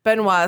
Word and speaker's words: Benoit: Benoit is correct. – Okay, that's how you pronounce it.